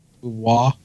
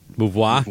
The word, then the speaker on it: Beauvoir: Do we all know how to pronounce it? Is it Beauvoir? – Beauvoir. – Beauvoir.